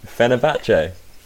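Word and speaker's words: Fenerbahçe: Fenerbahce.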